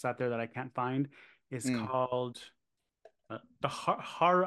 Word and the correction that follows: Harrower: I can't say it. H-A-R-R-O-W-E-R. Harrower. Harrower? – The Herald? – No, Harrower. Harrower, yeah.